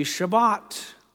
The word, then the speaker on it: Shabbat: The word Sabbath, or the Hebrew pronunciation, would be Shabbat.